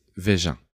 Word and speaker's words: Veja: But the right pronunciation is Veja. – Okay. – And it's a Portuguese word, mm-hmm. because Brazil speaks Portuguese. And it's the same in French, so we call it Veja.